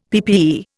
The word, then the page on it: PPE: Indian